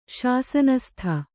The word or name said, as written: ಶಾಸನಸ್ಥ